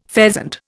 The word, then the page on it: pheasant: Indian